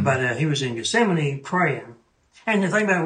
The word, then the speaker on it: Gethsemane: And the only time that you see him kind of a little shaken is in the Mountain of Gethsemane. – Mm-hmm. – Is that how you say it? – Oh, yeah, Mount Gethsemane.